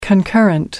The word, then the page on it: concurrent: American English